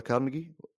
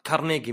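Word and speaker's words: Carnegie: كارنيجي ميلون ورحت اقرا وطلع النطق انه آه، هو كان يسمي نفسه كارنيجي بس بالانجليزي عموما ينطقونها كارنيجي لان الظاهر اسم اسكتلندي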